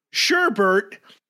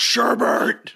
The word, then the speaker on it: sherbet: Sherbert is a common enough mispronunciation that they accepted. – Really? So what is Oxford, like, English has decided to like rope this one in? Like they're like, we'll let it go. – Yeah. – We'll let it go. – No, yeah. We just willed ourselves a new word. Because so many people were like, it's sherbet.